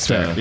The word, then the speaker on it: Tvaer: They're called Tver. – Okay, and how do you spell it? – T-V-A-E-R. It's like... – Okay. – I can't remember what Tver.